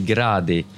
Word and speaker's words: gradi: That's correctly, more or less 450 gradi. A bit Italian.